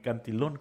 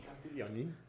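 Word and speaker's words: Cantillon: El compa no sabía que aunque Cantillón, ¿cómo me dijiste que se pronunciaba? – Cantilloni. – No es Italia.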